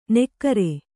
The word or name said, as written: ನೆಕ್ಕಾರೆ